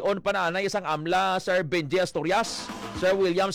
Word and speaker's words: AMLA: May iba siya nag-ahamba nga AMLAC, pero ang pronunciation is AMLA.